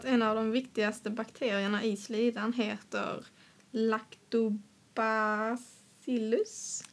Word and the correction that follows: Lactobacillus: Lactobacillus. – Jag tror det uttalas ja, så.